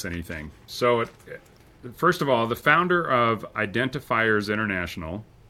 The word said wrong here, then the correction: Identifinders: Identifinders, stop. Everybody mispronounces it. Sorry. Identifinders. – I'm sorry. What did I say? – Identifiers. – Oh, shoot.